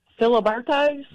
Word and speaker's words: Filiberto's: Say it again. – Filiberto's? – There you go. That's the right way to say it.